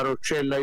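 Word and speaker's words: Roccella: Detto bene, siete a Rocella? – Sì, siamo a Rocella Ionica, fra un po' dobbiamo partire per Messina.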